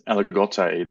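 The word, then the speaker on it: Aligoté: Spelt Aligote, pronounced Aligote, the only one of the three living varieties that are permitted in white Burgundy that include Chardonnay.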